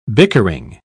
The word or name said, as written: bickering